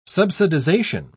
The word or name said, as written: subsidization